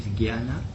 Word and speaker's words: Jnana: So can we say that the right pronunciation is Jnana? – Yes, Jnana. – Jnana. – Means when you put the J and that Na together as one letter... – Yes. – Then you have gya, yes.